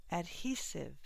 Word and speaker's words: adhesive: Adhesive, second syllable stress.